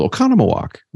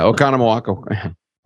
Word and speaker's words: Oconomowoc: Oconomowoc. Right? So some of you might know it. Some people call it, they think it's pronounced Oconomowoc. It's like Oconomowoc.